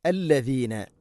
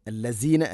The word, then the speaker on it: الذين: അല്ലദീന, അല്ലസീന അല്ല, അല്ലദീന ആമനുത്തഖുല്ലാഹ്, ആമനു ഇത്തഖുല്ലാഹ്.